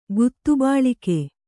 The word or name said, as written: ಗುತ್ತುಬಾಳಿಕೆ